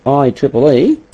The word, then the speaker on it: IEEE: The Institute of Electrical and Electronic Engineers, pronounced IEEE, are the people who sort of define these standards.